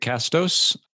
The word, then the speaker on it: Castos: I pronounce it Castos, but yep, it's good enough.